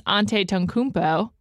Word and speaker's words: Antetokounmpo: While we're talking about the NBA, I have one tied to the Greek freaks, Giannis Antetokounmpo. I think I said that right. – You said it.